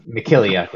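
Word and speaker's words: Mykhailiuk: They traded away, however you say his name. We butchered every time. – Spy. Just call him Spy. – Yeah, Mikiliuk is what it is.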